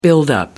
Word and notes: buildup: British English

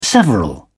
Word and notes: several: British English

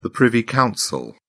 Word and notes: privy council: British English

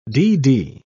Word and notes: dd: British English

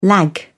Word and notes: lag: British English

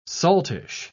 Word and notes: saltish: British English

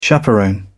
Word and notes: chaperone: British English

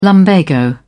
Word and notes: lumbago: British English

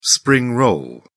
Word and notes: spring roll: British English

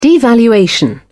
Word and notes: devaluation: British English

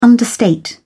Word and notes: understate: British English

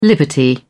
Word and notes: liberty: British English

